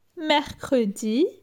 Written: Mercredi

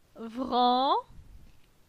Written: Vran

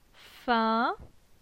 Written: Fin

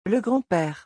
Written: Le grand-pere

